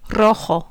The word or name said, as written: rojo